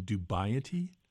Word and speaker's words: dubiety: And you actually, you dump a lot of this, is it the pronunciation dubiety? – Yes.